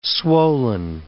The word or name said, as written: swollen